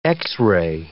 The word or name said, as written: x-ray